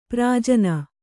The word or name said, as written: ಪ್ರಜನ